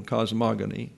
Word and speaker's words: cosmogony: It's an accent on the second syllable, cosmogony, whose definitions have evolved over the course of the last century.